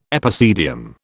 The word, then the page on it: epicedium: British English